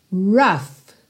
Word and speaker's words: rough: E atenção à pronúncia dessa palavra rough, ok? A escrita é R-O-U-G-H, mas esse OU vai ter um som de A e o g h vai ter som de F.